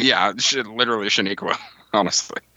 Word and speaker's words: Chaneque: That'd be the Chaneque.